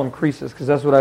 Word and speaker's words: Croesus: The real way the name is pronounced is Croesus. I've never heard it pronounced like that ever. Croesus. It's Croesus is the way that it's usually, or Croesus is the way that it's usually pronounced. It's the only way I've ever heard it. But in the pronunciation key that I have at home, they pronounce it Croesus, like a Z.